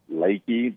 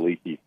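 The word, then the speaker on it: Leakey: Like, there's a town that's right near there called, called Lakey, but it's spelled like Leaky. I called it Leaky for about a decade.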